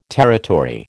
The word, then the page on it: territory: American English